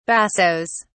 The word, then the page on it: bassos: British English